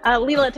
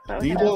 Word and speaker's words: Leila: Leila Thomasone. Yes, you got it right. – Oh, Leila. I thought it was Leila, like Eric Clapton. – Leila, like Sheila.